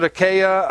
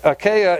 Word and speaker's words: Achaia: Some pronounce it Achaia, Achaia. We're going to go with Achaia. So the Achaia is right here, this whole area, and then Corinth is actually the capital of Achaia.